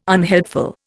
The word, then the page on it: unhelpful: Indian